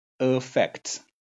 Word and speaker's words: affect: affect 这是它的读法，前面那个 a 呢发成 a，重音是在 f 那里。